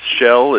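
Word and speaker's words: Kjell: Yeah, it's Shell. It's spelled Norwegian, so it's K-J-E-L-L.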